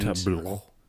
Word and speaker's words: tableau: Tableau. I think you pronounce it wrong. This is actually tableau.